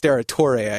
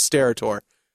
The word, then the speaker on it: Steratore: It's actually pronounced Steratore, I guess, but Al Michaels and Pat didn't want to ethnic up the show, so they kept referring to him as Steratore.